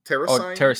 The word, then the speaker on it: Tarasaighn: Terasigan. – Terasigan? – Yeah, okay. Yeah, because that's spelled Tarasaighn.